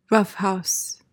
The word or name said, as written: roughhouse